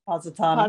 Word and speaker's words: Positano: To make matters worse, the mother daughter trip of a lifetime looms going to Pastiano. Did I say that right? – Positano. – Positano, yeah. Positano.